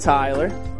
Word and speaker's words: Tyler: Tyler. – Tyler, can you spell that? – T-Y-L-E-R.